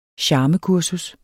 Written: charmekursus

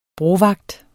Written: brovagt

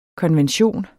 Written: konvention

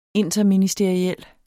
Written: interministeriel

